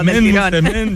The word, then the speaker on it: Msemen: Msemen, yo que sé, en otros lugares se conoce como ergaif. Pero una palabra complicada decía es M-S-E-M-E-N. Msemen, msemen, vale. Y se pronuncia como lo ha hecho Hakima.